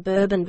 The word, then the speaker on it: Bourbon: For instance, the Bourbon variety, pronounced like the French last name and not like the Kentucky whiskey, is grown in parts of both Africa and Latin America.